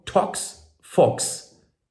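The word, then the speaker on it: ToxFox: Nimm doch einfach mal diese kostenlose App, die gibt es für Android und im iTunes Store, Tox Fox. Ja, wird genauso geschrieben, wie es ausgesprochen wird, mit zweimal X, Tox, Fox.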